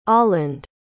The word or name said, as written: oland